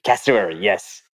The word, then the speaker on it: cassowary: Is it cassowary? Cassowary? – Cassowary, yes.